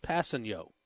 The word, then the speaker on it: Kpassagnon: And it's spelled, really interestingly, K-P-A-S-S-A-G-N-O-N. That's his last name, but it's pronounced Passanio.